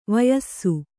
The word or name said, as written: ವಯಸ್ಸು